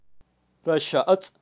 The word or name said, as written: raxa atz